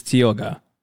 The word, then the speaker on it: Tioga: So it's named after something that is not equal to anything else, the Tiago Sequoia. – So this is a West Coast citrus. – I also... I might have said this wrong. So this is spelt Tiago. This is Tioga.